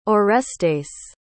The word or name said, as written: orestes